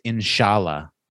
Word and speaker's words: inshallah: So according to the Oxford Dictionary, it is pronounced inshallah.